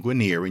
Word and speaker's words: Guinier: Guinier, G-U-I-N-I-E-R. That name traces back to a French word. It's a French verb, which today would be pronounced guinier. That's G-U-I-G-N-E-R, G-N in French, guinier.